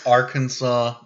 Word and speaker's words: Arkansas: Arkansas. It's pronounced Arkansas. – Yes. – Arkansas. – Arkansas. – I always have problems with that one. – Not your Kansas, Arkansas.